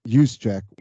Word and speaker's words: Juszczyk: Juszczyk was apparently the emergency QB.